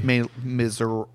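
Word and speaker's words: Les Misérables: It's pronounced Les Miserables.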